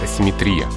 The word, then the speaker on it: асимметрия: асимметрия здесь без вариантов. Здесь ударение только такое.